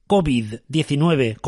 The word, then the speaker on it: COVID: uno, COVID-19, se escribe con mayúsculas, porque son siglas, que la pronunciación es aguda.